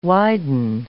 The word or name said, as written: widen